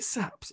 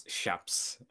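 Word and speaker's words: Shapps: Is it Saps or Shaps? – Shaps.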